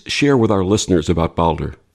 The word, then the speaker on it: Baldur: Baldur, is that correct? – Yeah, Baldur or Balder.